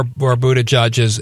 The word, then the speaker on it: Buttigieg: And as an impressionable person in his teens, I can see where Buttigieg is coming from.